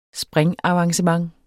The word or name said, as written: springavancement